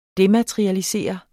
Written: dematerialisere

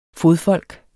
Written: FODFOLKET

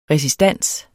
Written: resistans

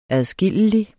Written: adskillelig

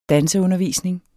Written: danseundervisning

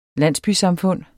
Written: landsbysamfund

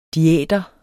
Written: diæter

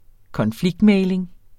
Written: konfliktmægling

